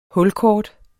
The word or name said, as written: HULKORT